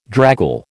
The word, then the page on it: draggle: American English